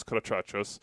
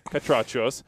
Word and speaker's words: Catrachos: And Bruce Arena and the guys went out and absolutely smoked Los Catrachos. And I said it wrong again. Catrachos. My bad.